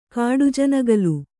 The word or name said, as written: ಕಾಡುಜನಗಲು